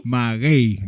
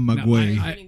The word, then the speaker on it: Maguey: It's my it's accent on the ah. – Maguey, not Maguey.